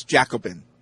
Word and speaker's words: Jaxobin: Remember we had the conversation about how J-A-X-O-B-I-N is pronounced? It's Jacobin.